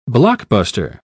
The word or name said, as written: blockbuster